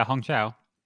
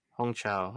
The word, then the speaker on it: Hong Chau: But the performances, so I think Hong Chau, I think I'm getting her name right. I don't know. How would you pronounce the name? – Yeah, Hong Chau. – Hong Chau, all right.